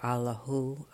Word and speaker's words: Allah: As Sufis, we use the name Allah, pronounced Allah. The sound Ah opens the heart. The heart resonates to the Ah sound.